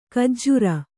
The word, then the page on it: ಖಜ್ಜೂರಿ: Kannada